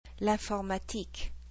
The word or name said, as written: l'informatique